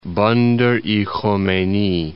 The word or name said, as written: Bandar Khomeyni